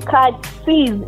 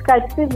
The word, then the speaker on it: Kakisizi: It's Susan with a S-U-S-A-N. Then Katsiz. It's Katsiz, which is K-A-K-I-S-I. Z-I. Like, you pronounce every letter in the word Katsiz.